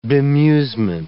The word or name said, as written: bemusement